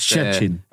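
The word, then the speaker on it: Szczecin: Jag tror väl så här att, eller jag skrev här för några veckor sedan att Jensas klubb, jag kan inte uttala det där, Pogon Chesney någonting, hade lagt... – Chechin. – Ja, Chechin.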